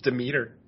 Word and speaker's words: Demeter: Yeah, I always said Demeter for like decades, just because I had never heard it spoken. But yeah, I guess it's Demeter.